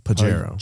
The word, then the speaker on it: Pajero: The car that actually, or the truck that actually won is the Pajero. – And that's what it's called elsewhere. – It's a soft J.